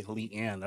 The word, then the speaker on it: Le-an: More of a Y in between my Lee and Ann, but yeah. – Okay, Leanne.